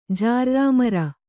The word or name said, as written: ಜಾರ್ರಾಮರ